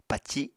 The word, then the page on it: paci: Apatani